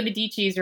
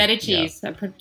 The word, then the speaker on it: Medicis: I'm watching the Medicis right now. I'm behind. – Oh, okay. – Or the Medicis, to pronounce properly.